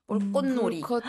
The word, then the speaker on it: 불꽃놀이: 일단 쌍기역이 들어가고, 그러니까 쌍기역 중간에 이렇게 들어가면은 저는 신경 안 쓰면 발음이 좀잘안될 때도 있어요. 불꽃놀이. 불꽃놀이. – 앞에 받침이랑 붙어서 더 어렵겠다. – 맞아요. 그리고 밑에 보면 볼꽃이잖아요. – 네. – 근데 또 이렇게 이어서 말하면 볼꽃놀이. – 귀여워. – 꽃놀이 되잖아요.